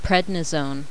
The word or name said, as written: prednisone